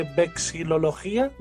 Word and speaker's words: vexilología: Pues apuntamos una segunda sesión de vexilología, ¿lo he dicho bien? – Sí, sí.